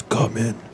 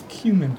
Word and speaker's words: cumin: It looks like it says, come in. – Sure. – Am I saying that right? Come in. – It's cumin.